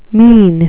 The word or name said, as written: mesne